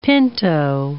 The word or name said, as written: pinto